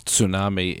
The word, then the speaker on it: tsunami: Tsunami, you pronounce it so well. – Thank you, yes. I learned that from George Takei on the Howard Stern Show. He taught everyone how to say it properly.